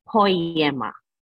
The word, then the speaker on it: Poiema: I think the way you pronounce it is Poiema. And the spelling is P-O-I-E-M-A.